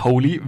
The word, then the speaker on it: Holy: richtig, richtig, Holy. Wie, so heilig, wie man spricht, schreibt man so.